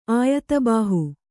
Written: ಆಯತಬಾಹು

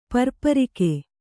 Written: ಪರ್ಪರಿಕೆ

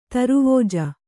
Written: ದಾರವಾಜ

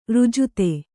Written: ರಾಜಿತ

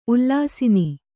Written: ಉಲ್ಲಸನ

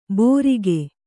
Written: ಬೋರಿಗೆ

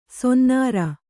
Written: ಸೊನ್ನಾರ

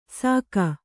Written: ಸಾಕ

